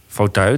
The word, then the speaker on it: fauteuil: Nou, hoe spreek je dat nou eigenlijk uit? Fauteuil? – Ja, fauteuil.